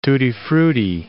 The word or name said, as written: tutti-frutti